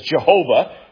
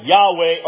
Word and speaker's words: Yahweh: Years and decades and centuries ago, the name was mispronounced as Jehovah. We still see that name in many of our hymns today. But because of the advancement of understanding of ancient Hebrew and Semitic languages, we think that the earliest members of the Old Covenant Church, they probably pronounce this something like Yahweh or Yahweh. We don't know for sure, but then we know that's probably a lot closer than Jehovah.